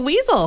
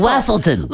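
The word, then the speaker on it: Weselton: Look at the weasel. – Look at the Wessel. – Wessel. It's pronounced Wessel. – Wesselton.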